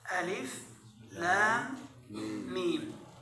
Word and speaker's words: الم: وتنطق هكذا ألف لام ميم. إن اللام والميم تمد مدا مشبعا، نعم، والألف تنطق هكذا، ليس فيها ألف، نعم، فتدغم الأولى في الثانية، ألف لام ميم.